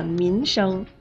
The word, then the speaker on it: 民生: 没错，这两个词，一个民生，一个名声，第二个字的发音是一样的，它们的区别呀就在第一个字上。民生的民是前鼻音，而名声的名是后鼻音。